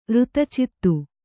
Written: ಋತಚಿತ್ತು

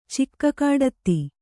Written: ಚಿಕ್ಕಕಾಡತ್ತಿ

